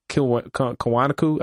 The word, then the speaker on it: Kiwanuka: Michael Kiwanuka.